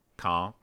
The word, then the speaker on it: Caen: We went to the, believe it or not, the 50th anniversary of D-Day at Carpique Airport in Cannes. Caen in France.